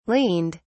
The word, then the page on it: leaned: British English